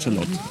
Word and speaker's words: Charlotte: Charlotte. Und ähm, also schreibt sich ganz normal mit E, aber wird ohne ausgesprochen.